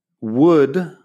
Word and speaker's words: would: Pero claro, would, que es como una forma condicional, un verbo auxiliar, un modal, pongamos, se pronuncia igual que wood de madera o bosque, que es W o O D. Pero wood, el verbo auxiliar, tiene la L que no suena.